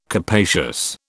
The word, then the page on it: capacious: British English